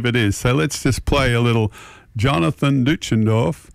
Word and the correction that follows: Deutschendorf: Bob Middleton tells me how to pronounce it. It's Deutschendorf. Anyway, John Denver.